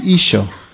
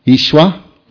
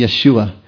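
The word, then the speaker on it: Isho (ܝܫܘܥ): But Dr. Erica would be the first one to tell you that in Aramaic it wouldn't be pronounced that way. It would be Isho. Ishwa. Ishwa. It looks like Isho, Ishwa, Isho, but it would not be Yeshua.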